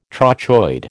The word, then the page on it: trochoid: American English